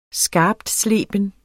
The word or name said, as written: skarptsleben